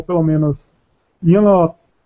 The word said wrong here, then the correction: Nyarlathotep: Eu não sei pronunciar, Nyanlotep. Nyanlotep. – Nyarlathotep.